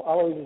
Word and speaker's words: Alois: Alois is just fine.